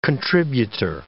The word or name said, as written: contributor